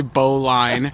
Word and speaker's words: bowline: It was so hard to hear him say bowline. I'm sorry. You don't say it how it's written.